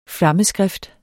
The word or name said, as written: flammeskrift